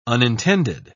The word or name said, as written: unintended